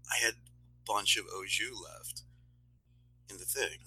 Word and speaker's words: au jus: It's pronounced au jus A lot of people say au juice or juice or whatever.